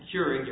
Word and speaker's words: Keurig: Since I'm a drinker of instant coffee, I don't know whether this is pronounced Keurig or Keurig. – Keurig, Your Honor. – Keurig.